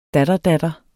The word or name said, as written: datterdatter